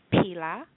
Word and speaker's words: pila: And the word for yellow is pila. It's pila. And it is spelled P-I-L-A. P as in Paul. I-L-A. And it's pronounced pila.